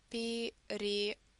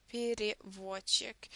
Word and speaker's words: переводчик: And also you can probably hear that in the beginning of this word, переводчик, I do pronounce the sound E, but you can see the letter here. The rule is the following, that when here in a word is in a non-stressed position, it's always pronounced as E sound.